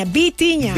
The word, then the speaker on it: Vitinha: Seguimos con Vitinha. No Bocinha, Vitinha.